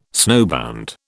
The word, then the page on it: snowbound: British English